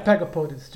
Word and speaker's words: pygopodids: Yes, they are snake-like, and I pronounce pygopids, but if you can. – Another podid in there? – Pygopodid, that's what I think. Pygopodids too.